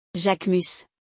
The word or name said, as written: Jacquemus